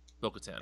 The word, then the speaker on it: Bo-Katan: Bo-Katan? I always mispronounce it. – Mm-hmm. – Bo-Katan. – I think that's right, yeah.